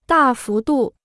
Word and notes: 大幅度: dà fú dù

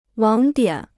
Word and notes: 网点: wǎng diǎn

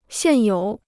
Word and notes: 现有: xiàn yǒu

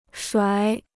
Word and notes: 甩: shuǎi

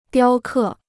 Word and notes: 雕刻: diāo kè

